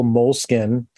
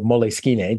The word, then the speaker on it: Moleskine: But the other part of it is, this is my little moleskin. Or moleskine, depending upon how you decide to pronounce it. Both are correct, according to my friend who is a marketing manager there.